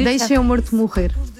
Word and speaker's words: Deixa-me Morto Morrer: No, you Deixa-me Morto Morrer. We eat the vowels, so it's very hard.